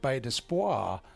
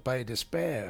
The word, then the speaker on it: Bay d'Espoir: So I was, because it was French, I was pronouncing it Bay d'Espoir. And the guys said, no, we don't call it that. We call it Bay d'Espoir.